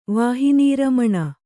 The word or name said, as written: ವಾಹಿನೀರಮಣ